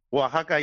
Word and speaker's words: Oaxaca: no sé cómo pronunciarlo bien. – Oaxaca, yo Oaxaca.